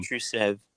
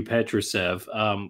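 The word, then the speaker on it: Petrušev: Petrusev. – Thank you, Petrusev.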